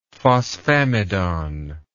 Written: phosphamidon

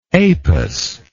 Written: Apus

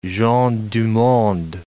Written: gens du monde

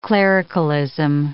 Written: clericalism

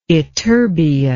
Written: ytterbia

